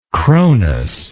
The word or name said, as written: cronus